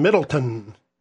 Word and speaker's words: Middletown: And then the party that we're attending is in Middleton, which is spelled Middletown, but the locals don't call it. Oh, they don't recognize the W.